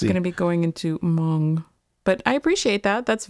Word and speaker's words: Hmong: There are a lot of H-M-O-N-G, which I'm going to say Hmong,